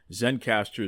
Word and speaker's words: Zencastr: And the reason why is because I am using Zencastr. Now, let me spell that for you. Z-E-N-C-A-S-T-R, Zencastr.